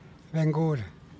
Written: ben guul

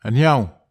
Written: aniaw

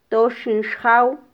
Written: toxin xjaw

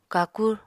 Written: kakur

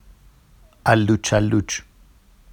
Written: alluʧalluʧ

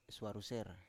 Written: swaruser

